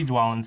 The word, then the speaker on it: Dwalin: Um, that one's Dvalin instead of Dwalin. – Mm-hmm. no, it would be Dwalin still.